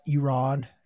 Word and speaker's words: Iran: Adam, it's pronounced Iran. It is my homeland. I will always have to correct guests when they come on. Not Iran, not Iranian, Iran.